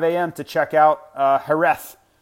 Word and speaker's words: Jerez: And you wonder why I'm saying it with a T-H at the end, because that's how they say it. Now, it's spelled J-E-R-E-Z, but that's not how they say it at all. It doesn't make any damn sense, but it is. It's Jerez. Silent J, and then instead of Z, they pronounce a T-H.